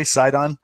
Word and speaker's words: Sidon: Am I saying that correctly? Sidon. – Yeah.